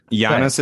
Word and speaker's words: Giannis: Giannis is correct.